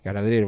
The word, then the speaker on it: Galadriel: No es Gabriel. No, Gabriel no, Gada, ¿cómo es Galadriel.